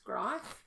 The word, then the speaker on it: Greif: I think it's pronounced Greif, even though it looks like Greif. G-R-I-E-F.